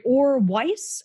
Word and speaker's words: Or Weis: Or Weis, is that how you pronounce your name? – Yes, that's exactly correct.